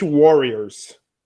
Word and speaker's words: Warriors: Me corrigiram a semana no vídeo a minha pronúncia. Vou fazer a pronúncia certa. Golden State Warriors.